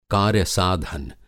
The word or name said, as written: कार्य-साधन